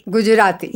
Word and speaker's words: Gujarati: Now, let me say if I'm saying that right, Gujarati. – Gujarati. – Yes. – Right, correct.